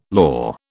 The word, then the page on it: lor: British English